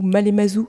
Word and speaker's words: Malemazou: Donc premier single qui s'intitule Cœur blessé, deuxième EP qui s'appelle MLMZ 2 ou Malemazou 2. – Bonjour Florence. Bonjour, je suis ravie d'être interviewée encore une fois par toi. Et oui, effectivement, le premier single du deuxième EP, Malemazou, tu l'as bien prononcé, qui veut dire Me voici, j'arrive, deux, parce qu'il y a eu un premier qui est sorti en 2022.